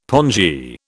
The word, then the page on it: pongee: British English